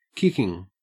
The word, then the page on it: kicking: Australian English